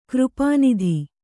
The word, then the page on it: ಕೃಪಾನಿಧಿ: křpā nidhi